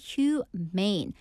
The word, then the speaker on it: humane: 자, human에다가 e를 끝에 붙였는데요. 그렇게 된다면 발음이 humane.